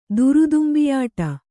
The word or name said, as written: ದುರುದುಂಬಿಯಾಟ